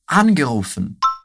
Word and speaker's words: angerufen: Angerufen. Angerufen. Sehr gut. Me diz aí. Conseguiu identificar qual que é a sílaba tônica? É na partícula an.